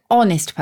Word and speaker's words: honest: But because we say an honest person and the H is silent, we put an an into it.